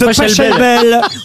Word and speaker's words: Pachelbel: Oui, parce que c'est le canon de Pachiavel et tout le monde le connaît. – Mais non, c'est Pachelbel.